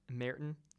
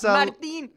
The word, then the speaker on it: Martin: And then this one's easy, but it's actually not how you say it. – Merton? – No, it's Martin. – Yeah, that is right.